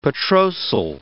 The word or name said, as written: petrosal